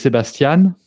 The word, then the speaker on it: Sébastien: So the way I'm called in French is Sébastien, and in English or German, it would be the way you pronounce it.